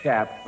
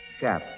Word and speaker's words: chaps: So many people do refer to them as chaps, but of course they are pronounced chaps. And the next one is R-O-D-E-O.